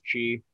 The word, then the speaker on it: Kyi: Aung San Suu Kyi. Is that chi. Right? – The last word there is chi. But yeah, you're close.